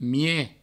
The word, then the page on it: mieg: mie